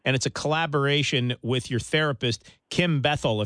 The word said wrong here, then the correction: Barthel: if I'm not mistaken, is the pronunciation, right? – Barthel. – Barthel? – Yes.